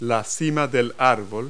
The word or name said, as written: treetop